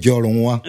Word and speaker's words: Jolowan: Ça se prononce BT Jolowan. Jolowan.